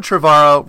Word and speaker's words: Trevorrow: All right, so Colin Trevorrow rhymes with tomorrow.